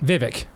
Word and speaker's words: Vivek: Vivek. – Vivek, okay. – Yeah. – Okay, so we have I haven't even had a chance to talk too much about this. I met Vivek last week.